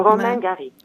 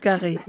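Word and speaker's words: Romain Gary: Πώς κανονικά πρέπει να προφέρω το όνομα? Φαντάζομαι ότι είναι κάτι ενδιάμεσο. – Ναι, είναι και κάτι ενδιάμεσο. Ρωμέν Καρί. Εντάξει. – Καρί. – Μια χαρά το προφέρατε.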